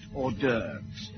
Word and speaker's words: hors d'oeuvres: Ooh, or trust horses, doofus. Actually, Chief, that's pronounced hors d'oeuvres.